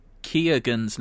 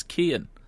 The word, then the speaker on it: Keoghan: I heard you talking about the killing of a sacred deer on the most recent episode and pronounce Barry Schmidt, let me get the right name, Perry Keoghan's name as Kean. His surname is actually pronounced Keoghan.